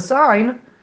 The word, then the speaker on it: sign: For some reason, the word signal, we pronounce the G, and the word sign, we don't pronounce the G.